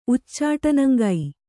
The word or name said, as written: ಉಚ್ಚಾಟನಂಗೈ